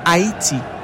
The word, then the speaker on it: Haiti: Just to also paint a clearer picture and be clear on that, the pronunciation is actually Haiti. Yes, the H is silent and it is Haiti, not Haiti, nor height.